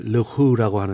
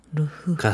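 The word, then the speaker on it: Leroux: 가스통 루루는 불어 발음으로 이제 르후라고 하는데요. – 가스통 루후. – 가스통 루후. 루후.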